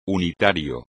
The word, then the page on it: unitario: espagnol